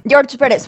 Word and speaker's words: George Pérez: Bueno, no, no sé si es George Pérez, George Pérez, ¿cuál es la pronunciación? – George Pérez.